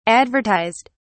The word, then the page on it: advertised: British English